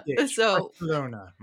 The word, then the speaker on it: Barcelona: So you had the unique pleasure of going to Mobile World Congress last week in Barcelona. I think I pronounced it correctly. – Barcelona.